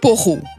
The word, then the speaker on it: poireau: Est-ce que vous dites poireau ou poro? Le mot s'écrit bien P-O-I-R-E-A-U et la prononciation attendue est donc poireau. Mais peut-être que vous dites quand même poro et que vous êtes tenté d'écrire poro éventuellement avec deux R pour bien montrer la prononciation neuchâteloise. Dans les dictionnaires actuels, on trouve seulement l'entrée poireau, donc P-O-I-R-E-A-U.